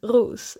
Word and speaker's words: Roos: Men då sa jag till honom, ja det är egentligen Ros. Men Ros är fine också.